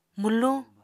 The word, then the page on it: mwluñ: Apatani